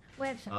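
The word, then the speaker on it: Güepsa: ¿Cómo se dice a los que nacieron en Huespa? Perdón, Huebsa. Websa. ¿Ah? Con diéresis.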